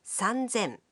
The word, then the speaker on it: 三千: Here too, the pronunciation changes. 3,000 becomes 三千. 8,000 becomes 八千.